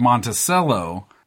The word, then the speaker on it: Monticello: Oh, I said the word Monticello. That's the way that I had always heard the Jeffersonian place pronounced. But you do pronounce C-E-L-L-O as cello, like the instrument.